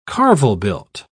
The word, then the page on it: carvel-built: British English